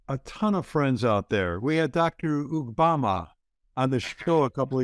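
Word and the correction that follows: Ugboma: Did I say that right? It was close. It was, it was close. Yeah. yeah, you say it now. Ukboma.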